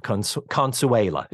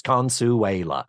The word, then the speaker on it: Consuela: Her maid, Consuela is the way she says it. Four syllables, Consuela.